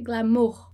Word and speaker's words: glamour: E em francês a gente vai dizer glamour, glamour, certo? E vai ter ali o O e o U, e vai fazer o som de U, glamour, glamour.